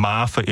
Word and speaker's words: Maafa: Okay, well, the title of this book is Beyond Maafa, and Maafa is spelled M-A-A-F-A. So Maafa is a Swahili word.